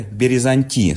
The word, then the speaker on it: березанти: и авестийское «березанти» с ударением на последнее «и».